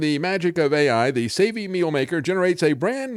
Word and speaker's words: Savey: The Savey Meal Maker, they spell it S-A-V-E-Y. I mean, you might think it's savvy, but actually this thing wasn't too savvy. It's a Savey Meal Maker.